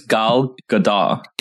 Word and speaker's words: Gal Gadot: I think you have to, is it Gal Gadot? – I think it's Gal Gadot. – Gal Gadot, I believe is Gal Gadot. Okay. How it's pronounced.